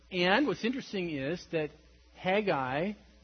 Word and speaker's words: Haggai: Haggai, which is pronounced Haggai, not Hey-guy.